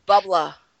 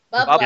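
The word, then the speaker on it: bubbler: They're calling water fountains bubblers, and don't pronounce the R, and you're good to go. – Bubbla. – Bubbla.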